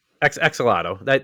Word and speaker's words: axolotl: And am I saying that right? Axolotl? – Axolotl. That's how my son always says it, at least. I'm going to follow his lead.